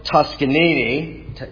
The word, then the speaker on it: Toscanini: The great conductor Arturo Toscanini, take my word for it, that's how you pronounce it, conducted Beethoven's Ninth Symphony.